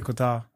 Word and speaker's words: Qatar: I said Qatar. – Qatar? – Qatar. – Qatar. – Qatar is good. Fuck, I had it in my head and I didn't want to mispronounce Kazakhstan.